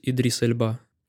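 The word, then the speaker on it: Idris Elba: Я, кстати, слышал, что правильно говорить Идрис Эльба.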